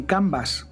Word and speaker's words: Canvas: cuidado, pronúncialo bien, Canvas, porque como te vayas un poco, acabas en el Kanban, ojito que es de tu amigo.